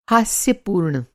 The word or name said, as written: हास्यपूर्ण